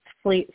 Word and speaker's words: Flea: Well, my name, again, is Felicita Hawkins, and people call me Flea. It's a childhood nickname. Some people don't like to call me that, but most people do. Flea, spelled like a bug, F-L-E-A.